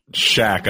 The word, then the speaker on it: Schack: NYU's Schnack? – Shack, unfortunately. Like, it's the most unfortunate naming of a real estate school.